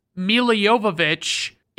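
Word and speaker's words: Milla Jovovich: Then we have Mila Jovovich, and if you're wondering, why did you pronounce it like that? I'll just say that it's because Mila Jovovich is the way she pronounces it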